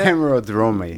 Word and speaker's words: hemerodromy: Well, you're a true hemerodry. – You said hemerodry. Is that how you say it? – Hemerodromy. – Yeah. – Hemerodromy.